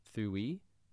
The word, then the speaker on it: Ddwy: That was followed by a very heavenly, angelic track from Thuwi. The track was called Beaming Backwards. Now I say Thuwi, but it's actually a Welsh word, so it's spelled D-D-W-Y, and it's a Welsh word that means two.